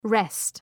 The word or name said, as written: wrest